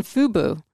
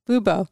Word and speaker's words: Fubo: You mentioned Hulu Live and YouTube, but failed to mention Fubo. I said it wrong. Fubo.